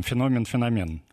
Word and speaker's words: феномен: Там, вот, например, там феномен-феномен. Угу. Э, одни считают, что феномен правильно, хотя всегда говорили раньше на моей памяти феномен, другие придерживаются того мнения, что это разные понятия вообще.